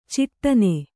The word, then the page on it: ಛಟ್ಟನೆ: Kannada